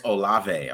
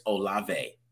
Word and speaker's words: Olave: Chris Olave. I want to make sure I keep saying that right, because I say Olive sometimes. Chris Olave.